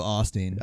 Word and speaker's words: Osteen: I've heard Osteen a couple of times, but predominantly I've always heard Osteen. – Yeah. So I'm going to go Osteen. – I think that's the way that it's pronounced.